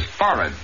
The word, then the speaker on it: forehead: The word is forehead, accent definitely on the first syllable, and the H is not pronounced.